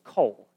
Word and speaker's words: Coll: The name was Cole. It's spelled C-O-L-L, and I thought it was just this perfect name.